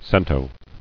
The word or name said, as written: Centos